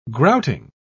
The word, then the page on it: grouting: British English